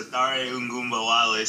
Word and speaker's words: Ogunbowale: How do you say his name? Do you know? – Okay, so Dare Ogunbowale signed with the Jaguars, and then Devin Ozigbo.